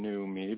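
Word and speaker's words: NEUMI: And so what they have, this company called New Me, it's spelled a little different than New Me, but that's how you say it. It's N-E-U-M-I.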